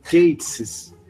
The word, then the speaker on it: Gatiss: It's pronounced Gatiss and no other way. Just think gay. I always do.